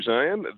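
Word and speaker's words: Zeihan: My name is Peter Zion.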